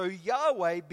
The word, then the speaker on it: Yahweh: Y-H-W-H. Amen. And so in the Hebrew language, Y-H-W-H does not have any vowels in it, which makes it impossible for us to pronounce. We can't pronounce that word in English. So what they did was they added some vowels to it, right? They added some vowels. They added an A and they added an E to it. And now we pronounce it Yahweh.